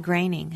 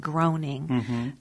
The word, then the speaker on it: Groening: The core members are, you know, Stephen King and Dave Barry, Ridley Pearson, and Matt Groening, who many people mispronounce as Matt Groening,